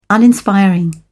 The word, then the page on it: uninspiring: British English